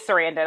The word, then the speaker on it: Sarandon: Sarandon. – Is it okay? It is Sarandon. I wasn't. – I'm pretty sure that's how it's said, but yeah.